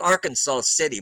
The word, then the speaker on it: Arkansas City: It's spelled like Arkansas City, but it's Arkansas City, Kansas.